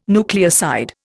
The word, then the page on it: nucleoside: Indian